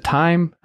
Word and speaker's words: Timme: how do you pronounce it? I don't remember now. Is it time? I, I, what is it? – Yeah, Timme's right.